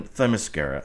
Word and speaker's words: Themyscira: Okay, so the great oracle of the internet says that it is spelled T-H-E-M, like them, Themyscira.